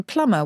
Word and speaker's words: plumber: Same, plumber. Why doesn't plumber rhyme with lumber?